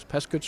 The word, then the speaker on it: Peascod: Pescud Street. It's spelt Peascod, but it's pronounced by the locals Pescud Street.